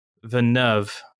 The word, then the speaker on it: Villeneuve: Villeneuve film.